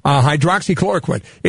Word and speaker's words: hydroxychloroquine: Hydroxychloroquine. – Yeah. Hydroxychloroquine.